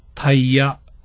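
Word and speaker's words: 大夜: これと同じ使い方が、大きい夜と書いてタイヤと読む場合なのです。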